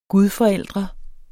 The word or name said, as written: gudforældre